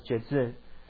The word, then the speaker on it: Jetsun: So the word venerable in Tibetan is pronounced as Jezun. So Jezun, you can spell as J-E-T-S-U-N, Jezun.